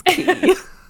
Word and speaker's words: quay: And quay is spelled Q-U-A-Y, in case you were wondering. – It's something that I have mispronounced, like, live to other people who knew how to pronounce it when I lived in Ireland, and people looked at me like, you silly American, that's key.